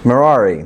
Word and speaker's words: Merari: Merari. Like Ferrari? All right, we'll go with that.